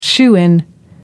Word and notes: shoo-in: American English

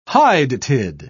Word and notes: hydatid: British English

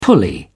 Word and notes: pulley: British English

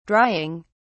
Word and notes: drying: British English

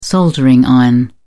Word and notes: soldering iron: British English

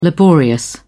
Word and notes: laborious: British English

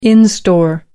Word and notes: in store: American English

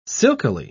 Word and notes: silkily: British English

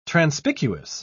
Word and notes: transpicuous: British English

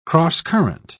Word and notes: crosscurrent: British English

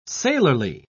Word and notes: sailorly: British English

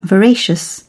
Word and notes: voracious: British English

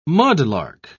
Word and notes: mudlark: British English

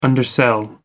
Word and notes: undersell: American English

